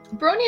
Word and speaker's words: brawny: Brawny is like big and muscly. – Yeah, that's the right word. – Just like Imper. – It's weird that it rhymes with scrawny, but yes, brawny.